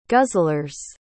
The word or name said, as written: guzzlers